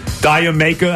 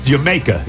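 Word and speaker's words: D'yer Mak'er: Diamaker, I think it's Jamaica. Isn't that how you say it? – Yeah, that's how I heard that Jamaica.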